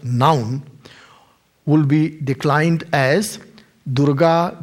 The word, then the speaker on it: Durga: Durga is, in mantric context, the word Durga is not Durga. It's not long A.